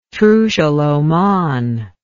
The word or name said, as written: trucial oman